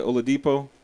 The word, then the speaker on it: Oladipo: Like, another thing that made that game pretty incredible for me was we saw your boy Oladipo.